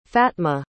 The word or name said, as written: fatma